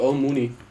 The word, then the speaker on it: Owen Mooney: Anders das englische Model Owen Mooney, ich weiß nicht, ob ich das jetzt richtig ausgesprochen habe. – Ja, das ist richtig, Owen Mooney.